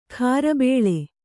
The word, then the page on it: ಖಾರಬೇಳೆ: khārabēḷe; dial.